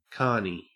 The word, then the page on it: carnie: Australian English